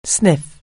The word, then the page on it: sniff: anglais